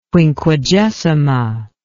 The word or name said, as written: Quinquagesima